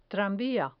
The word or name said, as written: tranvia